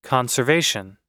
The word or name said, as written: conservation